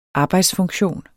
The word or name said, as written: arbejdsfunktion